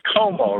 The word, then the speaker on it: Como: You know, the place you're moving to is Como, right? So it rhymes with Perdomo. You don't say Como and Perdomo.